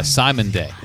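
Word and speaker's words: Cymande: Pronounced Simon. Oh, she actually put the pronunciation. Simon Day.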